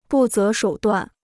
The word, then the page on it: 不择手段: bù zé shǒu duàn